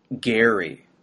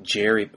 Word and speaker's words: Gerry: Elbridge Gerry was his last name. He pronounced it with a hard G sound. So we've ruined it by calling it Jerry.